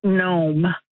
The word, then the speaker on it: gnome: O-M-E, mm-hmm. uncapitalized, and I'm wondering, is it gnome? – You have it correct. – Silent G. – Yes.